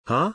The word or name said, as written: HUH